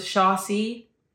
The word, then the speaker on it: Chaxiraxi: But for the podcast audio listeners, it's C-H-A-X-I-R-A-X-I. So imagine if that's wrong. – That's right. It's a mouthful. So yeah, Chikaraxi. I think we also came across another pronunciation, which was Sharsi.